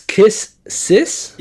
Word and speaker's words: Kiss x Sis: Is Kiss Sis? – Yeah, like yeah. Kiss X Sis. But the X is silent, like in Hunter x Hunter.